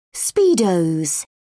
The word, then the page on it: speedos: British English